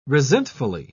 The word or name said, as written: resentfully